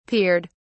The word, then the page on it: peered: British English